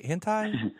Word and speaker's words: hentai: Hentai? – Yeah, hentai.